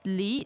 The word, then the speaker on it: Li: Now, it sounds like the word for pear, as in the fruit. – That's Li, second tone. – That's second tone. – So this is Li, third tone.